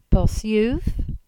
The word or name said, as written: Bosyuv